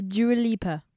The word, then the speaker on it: Dua Lipa: Je l'ai bien prononcé, ça va? – C'est Dua Lipa.